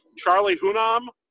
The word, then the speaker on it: Charlie Hunnam: But I went with, and I might need some help with the pronunciation on this, Charlie Hunnam? – Yeah.